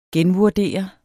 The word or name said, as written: genvurdere